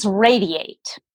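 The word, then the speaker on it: Radi8: It's actually, it's Radiate. So, sort of a play on the word.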